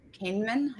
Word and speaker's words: Kahneman: The next book is Thinking Fast and Slow by Daniel Kahneman. Am I saying that right? – Yes.